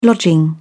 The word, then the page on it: lodging: British English